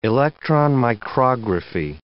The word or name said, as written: electron micrography